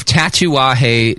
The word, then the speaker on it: Tatuaje: Now, Pete is involved with, he's actually the founder, owner of Tatuaje Cigars, which is a fairly new company, right, Pete? – And a mouthful, too. I'm glad that you're able to pronounce it correctly.